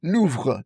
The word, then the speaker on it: Louvre: Louvre, Louvre. – 这法语发音比较难。它的拼法spelling 是 L-O-U-V-R-E. – So just in English, right? If you just want to say this in English, we just say the Louvre, Louvre.